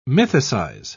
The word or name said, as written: mythicize